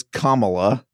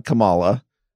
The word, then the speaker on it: Kamala: It is Kamala, not Kamala. And I knew that. Anyway, thanks to a listener, Gray, for calling us out on that. The big story of night two, though, was Kamala Harris challenging Joe Biden on his happy talk about working with segregationists and his position on school busing.